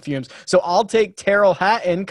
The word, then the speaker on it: Hatton: Hatton.